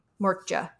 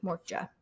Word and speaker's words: Moortje: Mortja, I think is how you say it. M-O-O-R-T-J-E, Mortja.